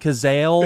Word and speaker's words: Cazale: Is it John Cazale? Yeah, Cazale.